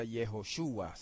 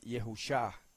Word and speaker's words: Yehoshua: Yehoshua. Se pronuncia exactamente igual. No se puede pronunciar Yehusha, como algunos hermanos erróneamente lo pronuncian.